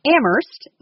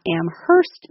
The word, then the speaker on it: Amherst: Amherst, not Amherst, Massachusetts, is a town of highly educated people who speak their mind. – There's a saying in Amherst that only the H is silent, meaning that people like to voice their opinions.